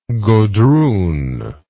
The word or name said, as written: godroon